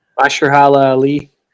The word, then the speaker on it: Mahershala Ali: So I'm going to butcher the dude's name, but I think it's Mahershala Ali. – Mahershala Ali.